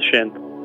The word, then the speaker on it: Shnth: A Shnth. I think that's how you say it. Yeah, the shinth. Yeah.